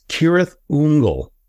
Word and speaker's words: Cirith Ungol: We've always pronounced CIRITH, UNGOL, the band. apparently, according to J.R. Tolkien, the correct pronunciation, I don't know if you've ever heard this before, it's CIRITH UNGOL, which I was like, I never knew that. So we've definitely Americanized that, let's say, to CIRITH UNGOL.